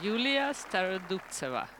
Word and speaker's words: Yulia Starodubtseva: Yes, Yulia Starodubtseva.